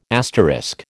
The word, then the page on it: asterisk: American English